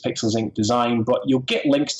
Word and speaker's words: Pixels Inc.: Okay, my business, Pixels Inc., and that's, or you could pronounce it Pixel Sync. It's easier to remember, but it's a K on the end.